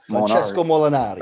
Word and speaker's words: Francesco Molinari: Francesco Molinari.